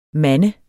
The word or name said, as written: manne